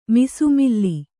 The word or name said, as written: ಮಿಸುಮಿಲ್ಲಿ